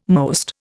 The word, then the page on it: most: Indian